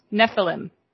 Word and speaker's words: Nephilim: According to the Encyclopedia Britannica, the term for giant is known as Nephilim, pronounced Nephilim. Nephilim.